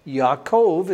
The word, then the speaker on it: Yaakov: And in chapter 49 is the story of where the biblical patriarch Jacob, Yaakov is how we say his name in Hebrew.